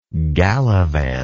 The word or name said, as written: gallivanter